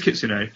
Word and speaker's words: Kitsune: And then, I don't know, I'm kind of, okay, it is Kitsune, fair enough. The E is there.